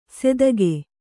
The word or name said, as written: ಸೆದಗೆ